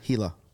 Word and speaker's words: Gila: I can't pronounce the place. – Gila. Perfect. – Gila. Thank you.